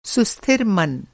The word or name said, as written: सुस्थिर